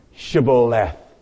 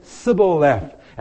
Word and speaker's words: Shibboleth: They said, okay, one word. Pronounce Shibboleth. And those people could not pronounce their S's, SH's, and they said Sibboleth.